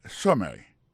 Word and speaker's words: summary: accent tombé sous première syllabe là summary ça c'est summary ça